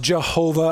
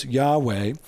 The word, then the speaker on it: Yahweh: That's the, in the Middle Ages, it was pronounced Jehovah. I prefer the ancient way to pronounce this, Yahweh.